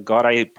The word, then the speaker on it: Godai: Uh, I just want to say that the Godai part of my name, that my surname is pronounced like that. Hmm. So I wrote it in a way that you would pronounce it like that.